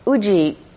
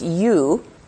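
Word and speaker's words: U (有): The word Uji can mean different things. The character U, right? The Chinese character U, which can also be pronounced Yu, okay, is a character meaning existence or being, okay, and the character Ji is the Chinese character for time.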